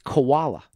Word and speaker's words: Chvala: It's C-H-V-A-L-A. – Vala. – He pronounced it koala. – Koala. – Like koala bear.